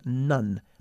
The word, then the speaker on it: none: Se escribe none, pero se pronuncia none, bajando la mandíbula.